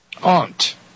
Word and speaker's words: aunt: How do you pronounce A-U-N-T? – Aunt. But it's aunt. My aunt. My aunt Ruby. – How do you pronounce it? – Aunt. I say aunt, like A-N-T. Aunt.